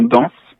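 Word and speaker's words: Dañs: Et donc ça se prononce c'est la nasalisation de la consonne, c'est-à-dire on prononce pas N mais en. – Donc en fait, c'est le nom que vous venez de citer, le titre, c'est tout simplement danse.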